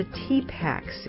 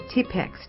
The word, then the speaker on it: Tipex: They're called the Tipaks. It's the English pronunciation of their real name, Tipeks, T-I-P-E-X, which means whiteout.